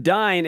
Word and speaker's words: Dyne: It, medical records, banking, having a two-word last name, V-A-N, space, capital D-Y-N-E, fucks people up. But Dine, it actually, it's Americanized. It comes from Dines, which is spelled D-U-I-N-E-S, and Dines, that means dunes, like we're the people that come from the dunes.